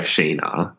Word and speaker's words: Scheana: Like Ra- Raquel got like punched in the face by Scheana. Is that how you say her name? – Yes.